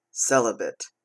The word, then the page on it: celibate: American English